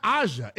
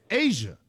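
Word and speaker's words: A'ja: You're not even saying it right. You're saying it's not Aja, it's Asia.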